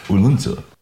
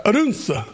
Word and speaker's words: Odense: We're live from the Nashville Knights International Songwriters Festival here in Odense, Denmark. – Odense. – Odense, yeah, that's right. Odense.